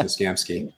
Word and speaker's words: Mashinsky: And this, I got to give you kudos here for calling this, because you've been on Alex Mashinsky. I think I got his name correct. You've been on him. – The ship is sinking. – It's actually Mashinsky. I know it's spelled Mashinsky, but it's pronounced Mashinsky.